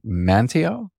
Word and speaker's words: Manteo: Let me see if I can say that right. Manteo? – You got it.